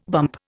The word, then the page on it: bump: Indian